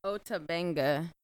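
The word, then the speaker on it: Ota Benga: Sarge Bartman's will eventually get forgotten, or the Otabanga's. Mm-hmm. Is that is how you say his name? – Otabanga. – Otabanga, yeah.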